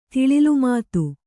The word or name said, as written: ತಿಳಿಲುಮಾತು